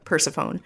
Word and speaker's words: Persephone: Well, I guess you've heard of Hades and Persephone because you know how to pronounce it. – Yeah. – Persephone. – I mean, Persephone, I remember.